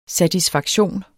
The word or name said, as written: satisfaktion